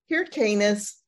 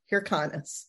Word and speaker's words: Hyrcanus: And through that whole class, I pronounced John Hyrcanus's name wrong. It's Hyrcanus, not Hyrcanus.